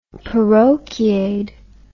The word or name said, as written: parochiaid